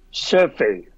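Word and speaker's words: chèvre: Chevre 呢个系法国名嚟噶，嗰、那个串法咧就系 Chevre， Chevre，一个法国发音嚟噶 Chevre，咁咧就法语就系山羊嘅意思啦。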